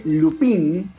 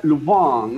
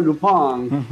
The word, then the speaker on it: Lupin: Eh, estamos hablando de la serie Lupin, eh, dicho en español, pero llevado al francés sería Lupin, ¿no? Lupin. raro, la pronunciación. – es que te das cuenta que se pronuncia así solamente si lo ves en el idioma original, que, está en Netflix, obviamente.